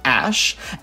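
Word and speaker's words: Æ: So the character AE, the one squished together, is apparently pronounced Ash, and A-12 is a CIA aircraft, and Archangel is the internal code name for that aircraft.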